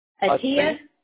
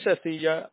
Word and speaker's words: Atteyah: Oh, maybe I'm pronouncing it wrong. A-T-T-E-Y-A-H, Athea? – Athea? – Uh-huh. Am I? – He's not in today. – So it is Athea, and he's not in today.